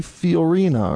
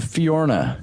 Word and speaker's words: Fiorina: Carly Fiorina or Fiorina. – I think you pronounce it Fiorna. – Fiorna. – I think it's almost like you just gloss over that second I in her name.